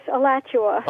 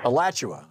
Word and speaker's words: Alachua: Tom Harper with you and Terry in Alachua, Florida. Am I saying it right, Terry? – Close. Alachua. – Alachua.